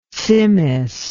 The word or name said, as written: tsimmes